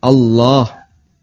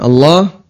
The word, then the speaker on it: Allah: Yang dipanjangkan adalah lafzul jalalah, Allah. Dan Allah, kalau pakai standar tajwid, berarti dia dibaca tafkhim, Allah. Yang kedua, panjangnya dua harokat, Allah, dua harokat.